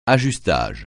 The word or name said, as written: ajustage